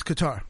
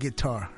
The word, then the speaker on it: Qatar: Qatar? – It's Qatar. No, not guitar.